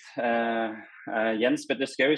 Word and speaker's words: Jens Petter Skaug: Jens Petter Skaug is my name, and I'm from Norway.